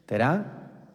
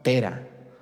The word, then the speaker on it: Terá: Se llamaba Tera. Acento en la A. Tera. Su mamá de Abraham se llamaba Terá.